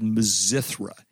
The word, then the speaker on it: Mizithra: It's called Mazithra. And it's an outstanding cheese. It's spelled Mizithra.